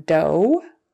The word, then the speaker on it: dough: So in those 2 cases, O-U-G-H is pronounced ooh. And then O is another way, O, as in dough, although, thorough, sourdough, furlough, and just though.